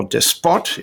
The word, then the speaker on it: Despot: and we'll be having Slobodan Despot, or Despot, if you wish to pronounce it in English,